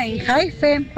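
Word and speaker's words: ENHYPEN: Hola, en Hypen, por favor, en Hypen. – En Hypen, así se pronuncia, en Hypen.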